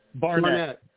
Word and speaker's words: Barnett: No, first thing I wanted to ask, is it Josh Hartnett? – Barnett. – Barnett, B-A-R-N-E-T-T? – Yes. – I just want to make sure we all got the name right. It's J-O-S-H, Josh Barnett.